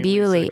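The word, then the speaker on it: Bewlay: Pronounce it again for me. – Bewley. – Lisa, it's the Bewley Brothers.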